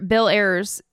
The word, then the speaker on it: Ayers: Bill Ayers, am I saying his name right? – That's right, Bill Ayers.